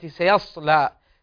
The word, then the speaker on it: سيصلى: سيصلى نارا ذات لهب، كلها مرققة عدا في كلمة سيصلى. سيصلى، حرف الصاد هنا مفخمة، فنراعي فيه ارتفاع أقصى اللسان.